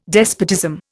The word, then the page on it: despotism: Indian